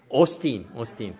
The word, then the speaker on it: Osteen: Osteen or something like? Austin.